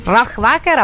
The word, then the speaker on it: Rohrwacher: Rohrwacher, applausi perché l'ho detto bene, applausi finti, applausi lontani, applausi solo educati, perché quando uno li chiede poi la platea risponde solo per educazione.